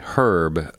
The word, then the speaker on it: herb: And so, just to define our terms, what is the difference between a spice and an herb, or as you say in the UK, herb. Uh, we don't pr- we don't pronounce the h.